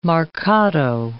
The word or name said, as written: marcato